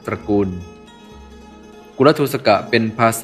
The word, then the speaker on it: กุลทูสก: สวัสดีครับวันนี้ก็พบกับรายการคำวัดวันนี้เสนอคำว่ากุลทูสกะ